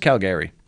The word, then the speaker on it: Calgary: Is Calgary known for oil? – It's pronounced Calgary. – Calgary? Are they known for oil or no? – It's Calgary, first of all. – It's Calgary. Get it right.